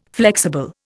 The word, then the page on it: flexible: Indian